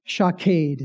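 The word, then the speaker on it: shaked (שָׁקֵד): The word for an almond tree is shaked in Hebrew, shaked. And the word for I am watching is shokade. It rhymes.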